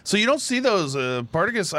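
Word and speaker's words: Partagás: It was the Partagas? – Yes. That's the way I say it. Wow, Partagas. – I put the emphasis on the wrong syllable there. the Partagas Añejo Esplendido.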